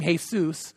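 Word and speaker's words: Jesús: By the way, he pronounces his name Jesus.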